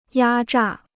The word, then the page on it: 压榨: yā zhà